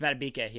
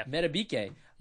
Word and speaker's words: Madubuike: Madubuike? – You got that? – I'm pretty sure that's Madubuike. Yeah. – Madubuike.